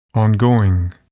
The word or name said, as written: ongoing